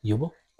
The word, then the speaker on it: Uebel: Uebel. – In German, you pronounce it Uebel, but that's a train wreck here.